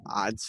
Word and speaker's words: Adz: Odds is spelled ADZ, if you're wondering. Okay. It's just pronounced odds for some reason.